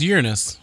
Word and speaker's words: Uranus: How do you want me to pronounce Uranus? – It's Uranus.